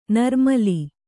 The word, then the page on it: ನಿರ್ಮಲೆ: nirmale